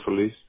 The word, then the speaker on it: Solis: Aldo Solis. And Fortner. – Thank you. And then Solis is spelled S-O-L-I-S, just to make sure, right? – Yes.